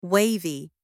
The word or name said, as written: wavy